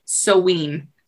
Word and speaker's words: Samhain: What looks like Samhain, but isn't pronounced that way. And I can never remember how it's actually pronounced. – I actually wrote it. It says it's pronounced Soween.